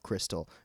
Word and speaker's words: Krystal: And if you don't live in the Southeast, I think you may not even be familiar with the restaurant of Crystal. It's spelled K-R-Y-S-T-A-L,